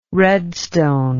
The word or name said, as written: redstone